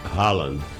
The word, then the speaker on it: Haaland: Haaland. – Erling Haaland, é isso ou não? – Erling Haaland.